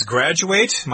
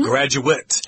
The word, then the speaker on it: graduate: A similar word is graduate. My wife makes this mistake all the time. Graduate is the verb. Mm-hmm. Graduate is the noun.